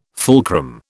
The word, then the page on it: fulcrum: British English